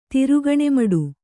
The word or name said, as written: ತಿರುಗಣಿಮಡು